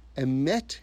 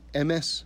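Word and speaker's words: Emet (אמת): Emet in Hebrew, or if the Ashkenazi pronunciation, Emes, right? Same word.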